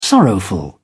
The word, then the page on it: sorrowful: British English